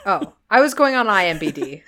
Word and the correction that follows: IMDb: IMDB? – Yes, I literally say it wrong every single time I say it.